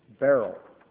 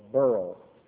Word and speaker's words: beryl: Now, next we come to beryl or burl. however you may wish to pronounce it, B-E-R-Y-L, B-E-R-Y-L, pronounced Burl, or Beryl, that's a hard one to say just right.